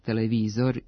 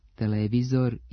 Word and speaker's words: televizor: Imenice televizor i radijator, koji imaju dugosilazne akcente na trećem slogu, pravilno se akcentuju kao oblici sa kratkouzlaznim akcentima na drugom slogu i dužinom na mestu starog akcenta, televizor i radijator.